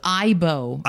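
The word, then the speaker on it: aibo: He says, Ebo offers early evidence that... – Oh, it's Ibo. – Ibo. – It's pronounced, yeah. – Ibo. Ibo.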